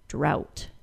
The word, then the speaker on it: drought: All of those words have a letter T following the O-U-G-H. So if you see O-U-G-H-T, you can assume an ought pronunciation. There is, of course, a word that doesn't fit the pattern, and that word is the word drought, which uses the OW sound instead.